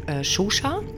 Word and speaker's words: Xuxa: Die wird geschrieben X-U-X-A, ausgesprochen wird sie Xuxa, die Spukpuppe.